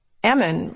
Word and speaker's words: almond: Almonds are indeed a serious business in Winter's, and we were told that you can tell a Winter's native by the pronunciation of the word. Ammon rhymes with famine. The joke goes that you have to shake the almond trees so hard to get the nuts to fall that you shake the L right out of the ammon.